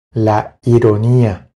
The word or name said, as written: La ironia